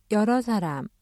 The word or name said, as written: 여러 사람